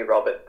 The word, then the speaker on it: Robert: Like my nickname is Lobber because people can't pronounce my name properly in Chinese. So they would instead of calling me Robert, they call me Lobber.